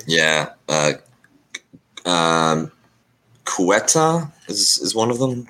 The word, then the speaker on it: Ceuta: Uh, I, I, mean, I, I, know how it's spelled, but I, I didn't know how it was spelled uh, said until you just said it. I would have said like Quetta or something.